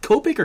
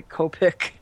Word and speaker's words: Copic: A lot of people say Copic. You know, it's. I mean, if you're. – Is it the preference thing, huh? – If you're gonna, you know, go the whole Japanese pronunciation snob route, I guess it's probably Copic. – Copic.